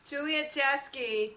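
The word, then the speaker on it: Juliet Jeske: Juliet Jasky, Juliet spelled like Romeo and people.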